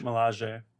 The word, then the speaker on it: Milaje: okay, so you guys are going to have to help me with the pronunciation. Dora Milaje? Milaje? Milaje? – Milaje. – Milaje. Okay, Dora Milaje.